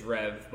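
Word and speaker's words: Rêve: It's spelled R-E-V-E. How would you pronounce that? – R-E-V-E? There's so many breweries in Jacksonville now. There are. – R-E-V-E? – Yeah. – Rev? – See? Thank you. That's the right way.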